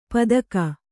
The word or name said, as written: ಪದಗ